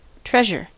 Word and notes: treasure: American English

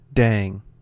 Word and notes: dang: American English

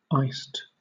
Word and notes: iced: British English

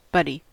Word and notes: buddy: American English

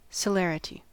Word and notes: celerity: American English

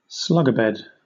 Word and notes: slugabed: British English

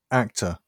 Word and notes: actor: British English

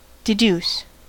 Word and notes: deduce: American English

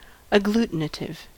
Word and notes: agglutinative: American English

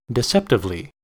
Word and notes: deceptively: American English

